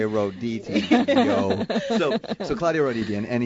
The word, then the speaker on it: Roditi: how would you say that, Aline? – Claudio what? – Roditi? – Rochichi? – R-O-D-I... – It's R, so it's Ho.